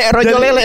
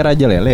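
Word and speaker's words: Rojolele: Yang benar iya, rojo lele, rojo lele yang benar. Iya, rojo lele. Raja. Tapi bukannya di mereknya raja lele ya namanya? Rojo, rojo. Bacanya rojo.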